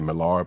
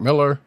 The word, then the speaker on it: Millar: I keep wanting to say Millar, but Mark Miller, which I still can't believe that's how you pronounce his name for some reason after all this time.